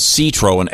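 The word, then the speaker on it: Seetroën: These are called Citroën, S-E-E-T-R-O-E-N, umlaut over the E, or whatever that's called.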